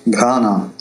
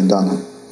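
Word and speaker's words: Дхана (धन): Дхана — там «х» придыхательное. Вот. Но в русском языке «х» очень по-другому произносится, поэтому, может быть, даже иногда правильнее говорить без «х» Дана.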